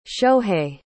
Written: shohei

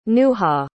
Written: nuha

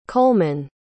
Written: colemann

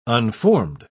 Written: unformed

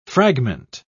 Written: fragment

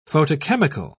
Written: photochemical

